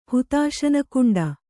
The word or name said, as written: ಹುತಾಶನಕುಂಡ